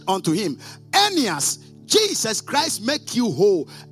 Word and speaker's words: Aeneas: And his name was, actually we pronounce it like the way we pronounce Ananias, but it is Aeneas. Do you get it? It is Aeneas. So, or Aeneas. But that is how we pronounce it.